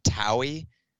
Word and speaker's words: TOWIE: correct me on the pronunciation, is it Towie? – Yes. – Okay, Towie. Yep, I can pick up Towie.